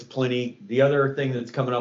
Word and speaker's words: Pliny: The other interesting things folks might care about is Pliny is actually not pronounced Pliny. It's pronounced Pliny.